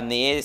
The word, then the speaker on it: Anet: Só pra galera que tá ouvindo, é, a Nê se escreve com T no final. – Né? Isso, né? T mudo.